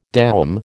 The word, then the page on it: doum: American English